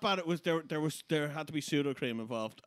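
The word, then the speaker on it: Sudocrem: Speaking of, fun fact, pseudo cream is... – It's gone now, I hear. – No, it's Irish. And the reason it's called pseudo cream is because it is the Dublin pronunciation of soothing cream.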